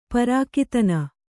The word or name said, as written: ಪ್ರಾಕ್ತನ